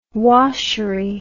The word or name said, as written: washery